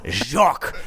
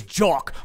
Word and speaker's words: Jock: It's not Jock. It's not a soft J. It's Jock, like a protective penile cup.